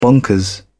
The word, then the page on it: bonkers: British English